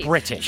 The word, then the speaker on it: British: You know, gotta put that proper emphasis on there. Yeah. – Gotta say the T. – British.